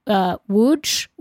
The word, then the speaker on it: Łódź: They were from Łódź, which it's spelled L-O-D-Z with a slash in the O, but it's pronounced Łódź or something, I guess.